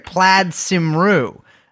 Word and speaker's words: Plaid Cymru: Well, I was actually sent by the CIA to infiltrate Plaid Cymru Oh my um,